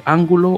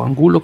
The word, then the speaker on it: Angulo: bueno, de los jugadores que tenemos primeramente en Pilar de los jugadores colombianos que están, tenemos a Carlos Ángulo, eh, o, Ángulo Angulo creo que se pronuncia en este caso, eh, y a él lo recuerdo porque de hecho estuvo jugando en Carrasco Polo en, Montevideo, Uruguay.